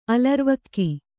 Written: ಅಲರ್ವಕ್ಕಿ

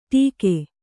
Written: ಟೀಕೆ

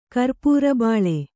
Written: ಕರ್ಪೂರಬಾಳೆ

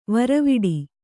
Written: ವೀರವಟ್ಟ

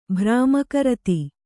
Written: ಭ್ರಾಮಕರತಿ

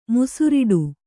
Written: ಮೊಸರೊಡೆ

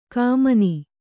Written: ಕಮನಿ

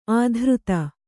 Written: ಅಧರಿತ

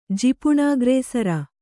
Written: ಜಿಪುಣಾಗ್ರೇಸರ

